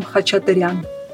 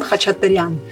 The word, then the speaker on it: Khachaturian: Ca- as Hach- in Khachaturian. – Right. – Khachaturian. I, have heard it pronounced Khachaturian. Yes. Really? Oh, maybe not in. So yeah. Armenian pronunciation would be Aram Khachaturian.